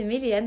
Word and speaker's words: Méléane: Du coup, mon Insta, c'est Méléane. Donc, mon prénom s'écrit M-E-L-E-A-N-E, e e, mhm.